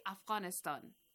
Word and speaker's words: Afghanistan: So we try to pronounce it like they're Afghan people. We say Afghanistan. That's the way.